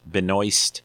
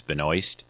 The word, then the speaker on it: Benoist: and stars Melissa Benoist. Benoist. Um, I, I, I, always try hard to say her name correctly. I've always heard it, it rhymes with moist, so it's Benoist.